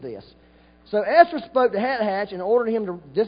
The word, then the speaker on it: Hathach: How do you say that, Ryan, since you're so good? You don't know. It's Hat Hatch. H-A-T-H-A-C-H. How else are you going to say it?